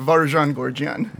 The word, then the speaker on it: Varoujan Gorjian: Varajan Gorgian. – Varajan Gorgian. – My wife Allison came up with this way of saying you put your rouge on and it's Varajan.